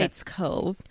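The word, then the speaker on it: Hite Cove: Yeah, you said Heights Cove. – Like, yeah, it's spelled H-I-T-E, okay. Heights Cove, in the Sierra National Forest.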